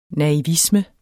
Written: naivisme

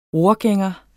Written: ror

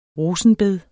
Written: rosenbedet

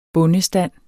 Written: bondestand

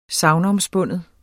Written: SAGNOMSPUNDNE

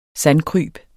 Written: sandkryb

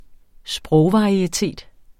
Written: sprogvarietet

